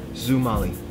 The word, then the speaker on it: Xumoli: How do you spell it? – X U M O L I. – Zumali.